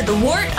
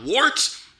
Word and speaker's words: Wort: It is The Wort. Not wart, not fort, not short.